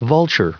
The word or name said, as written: vulture